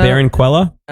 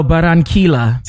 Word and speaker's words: Barranquilla: Barranquilla? – No, Barranquilla.